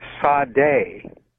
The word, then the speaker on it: Sade: And at one point, one of them telepathically told her to call either her or the group, Sade, S-A-D-E, she said is how it's spelled.